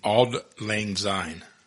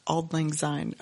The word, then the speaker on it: Auld Lang Syne: Dance to Auld Lang Syne. – Auld Lang Syne. – Auld Lang Syne.